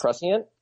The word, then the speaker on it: prescient: I mean, precision, did I say that wrong? I was smart because it's prescient. – Prescient.